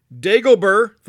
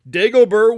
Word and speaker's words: Dagobert: Dagobert was 11 or 12 at the time and ruled for just 4 years. The French ending, pronounced Burr, is spelled B-E-R-T.